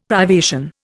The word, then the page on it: privation: Indian